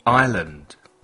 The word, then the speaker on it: island: Isla. Island. La S no se pronuncia. Escucha. Island. Repeat. Island.